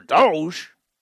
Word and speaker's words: Doge: "You're Doge? What are you talking about?" Much wow indeed. – Is that serious? Is that how you say it? Doge. – Dogecoin, yep.